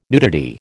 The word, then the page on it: nudity: American English